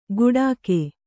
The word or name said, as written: ಗುಡಾಕೆ